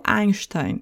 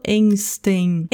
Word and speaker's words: Einstein: O outro ditongo dá para associar com uma outra figura histórica que é o Einstein. Eu não falo Einstein.